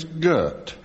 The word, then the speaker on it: Gert: His first name was Gert. – That's how you pronounce it, actually.